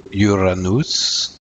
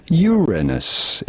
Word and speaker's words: Uranus: It is generally accepted that it is preferable to stress on the first syllable when you say it rather than on the second one, and say Uranus rather than Uranus, although both pronunciations are possible and correct, a matter of personal preference.